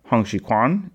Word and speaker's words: Hong Xiuquan: So I have been saying Hong Xuchuan. It's more accurate to say Hong Xuchuan, or something closer to that. So from now on, Hong Xuchuan is Hong Xuchuan.